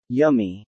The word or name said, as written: yummy